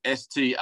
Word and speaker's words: Australia: If you think of it spelled Straylia, Australia, that's how we say it over here. No one pronounces the A, U. It's Australia. If you're ever hanging out in Australia and you want to talk about Australia, just call it Australia.